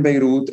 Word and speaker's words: Beirut: So the official spelling in English is B-E-I-R-U-T, while the French spelling, it's B-E-Y-R-O-U-T-H. But we wanted to go with what it sounds like, or however you want, or however you say it or pronounce it. So it's Beirut, B-A-Y-R-U-T, which is more closer than the other spellings.